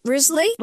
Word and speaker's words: Risley: Risley? – Yeah, it's Risley, like the bear without the G.